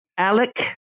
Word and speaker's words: Alec: Since 2014, Alec, also called Alex, it's spelled Alex, it's said predominantly Alec, which I think mm-hmm.